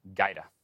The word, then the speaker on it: Gajda: G A J D A is how you spell his last name, pronounced Gaida.